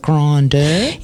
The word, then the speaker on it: Grindr: Is that how you say it? Grindr? – Yeah.